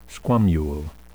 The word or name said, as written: squamule